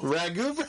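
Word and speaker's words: Rougeau: The other team is the I might butcher this, the Ragu Brothers? – Ragu.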